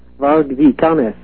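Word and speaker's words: Varg Vikernes: Varge Vikernes is the way he pronounced it.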